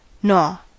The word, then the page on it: gnaw: American English